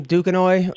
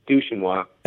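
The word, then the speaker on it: Duquesnoy: Tom Dukanoy, or how do you say his last name? – I think it's Dushanwa.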